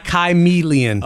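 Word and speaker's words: Kymellian: I think you mispronounced chameleon. – No, it's Chimelion.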